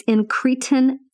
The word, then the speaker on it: incretin: I had to go look and figure out how to pronounce it. Incretin.